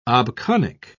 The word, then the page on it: obconic: British English